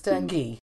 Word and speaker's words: dengue: I got it. It's dengue.